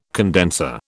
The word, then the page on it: condenser: British English